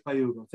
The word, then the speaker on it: Ugo: Yes, it's Ugo Balta. And even Hugo, what I meant was they would mispronounce my name like Hugo or Hugo. And, you know, without even sound, not even properly in English.